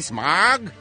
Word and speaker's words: Smaug: How do you spell that Smaug? – S-M-A-U-G. – Because I would say Smaug. – Smaug. – Would you say Smaug? – It's Smaug.